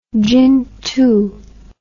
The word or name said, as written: Jingtu